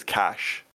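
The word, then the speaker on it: cache: But I think the way you pronounce the word that we're looking at is cash.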